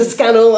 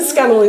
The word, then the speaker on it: Scannell: So Google Linda Scannell, I will come up.